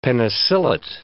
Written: penicillate

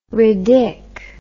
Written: ridic

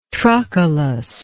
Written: trochilus

